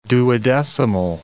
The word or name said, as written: duodecimal